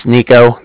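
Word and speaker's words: Niko: My name's Nico. That's N-I-K-O.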